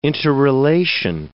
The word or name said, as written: interrelation